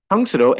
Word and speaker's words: Tangsudo: So again, Tangsudo, and thank you for pronouncing it correctly. Most people here in the States say Tangsudo or Tangsudo or something like that. So it's nice when somebody says it correctly.